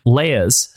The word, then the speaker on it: Leia: Cell 2187, Leia's cell number from A New Hope.